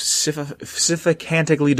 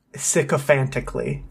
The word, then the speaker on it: sycophantically: Utilizing both, the Master rallied an army of sycophantically devoted humans and super mutants. – Close enough. – What? How did I say it? – Wrongly, but close enough. – How do you say it? – Sycophantically.